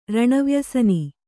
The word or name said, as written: ರಣವ್ಯಸನಿ